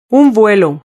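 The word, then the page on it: un vuelo: Spanish (Spain)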